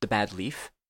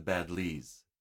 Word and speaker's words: Badlees: The Bad Leaf? – The Badlees. Badlees. – Oh, Badlees.